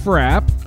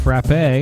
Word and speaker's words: frappe: And I looked up how to pronounce that word. It can be pronounced either way, frappe or frap. But they mean two different things. Frappe is an icy fruity beverage. A frap is more like a milkshake.